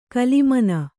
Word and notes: ಕಲಿಮನ: kalimana

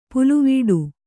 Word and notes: ಪುಲ್ಲಿವೆಟು: Kannada

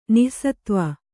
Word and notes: ನಿಸ್ಸತ್ವೆ: nissatve

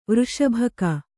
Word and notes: ವೃಷಭಕ: Kannada

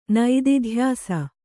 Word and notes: ನಿದಿಧ್ಯಾಸ: nidi dhyasa

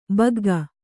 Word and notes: ಬಗ್ಗ: bagga; Kannada

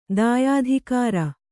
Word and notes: ದ್ಯುತಿಗರೆ: dyutigare

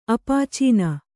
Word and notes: ಅಪಾಚೀನ: apācīna

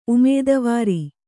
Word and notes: ಉಮೇದವಾರಿ: umēdavāri

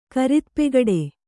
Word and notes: ಕರಿತಪೆಗಡೆ: Kannada

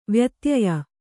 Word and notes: ವ್ಯತ್ಯಯ: vyatyaya

